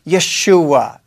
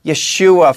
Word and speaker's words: Yeshua: And when you hear the name Jesus, the very name Yeshua, as it's pronounced, Yeshua, from we go back even into the Old Testament, Joshua.